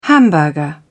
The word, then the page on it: hamburger: British English